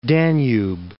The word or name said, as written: Danube